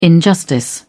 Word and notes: injustice: British English

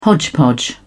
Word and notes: hodgepodge: British English

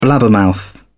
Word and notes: blabbermouth: British English

